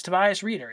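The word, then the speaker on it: Tobias Rieder: How do I say his name? It's Tobias Reeder, isn't it? – Correct.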